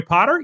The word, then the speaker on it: Potter: My name is Jerry Potter. Yes, it rhymes with the boy wizard, host of the Social Media Marketing Talk Show, a Social Media Examiner production.